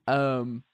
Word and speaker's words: Pache: And then you're asking Christian Pache, or how do you say his last name? I've heard it like three different Pache. – Ways. – Is it Pache? – Yeah. – Pache.